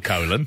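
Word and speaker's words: Colón: Wikipedia, it is spelled colon. yeah. yeah. but there has got like the uh, the um, apostrophe over the top of the oar to indicate.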